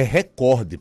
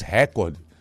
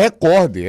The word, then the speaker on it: recorde: Gente, a pronúncia correta é recorde, pelo amor de Deus, não falem mais recorde. A palavra é recorde, ela é uma paroxítona, a sílaba forte é o cor.